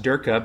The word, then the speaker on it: Durka: I know that his name is pronounced Durka because whenever John says it, it's Durka, D-U-R-K-A.